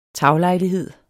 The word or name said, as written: taglejlighed